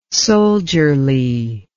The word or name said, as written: soldierly